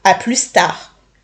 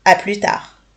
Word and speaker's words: à plus tard: Attention, quand je dis la version longue, je ne prononce pas le S. Je ne dis pas à plus tard, mais bien à plus tard.